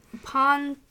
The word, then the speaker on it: Pontoppidan: Pontoppidan.